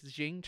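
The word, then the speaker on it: Qing: She actually got – her forces got into fights with, like, the East India Trading Company, the Portugal Empire, and I think it's Jing China is how you say that.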